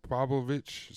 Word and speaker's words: Pavlovich: Aspinall is going to fight Sergey Plovok. How do you say it? Sergey Pavlovich, September 4th.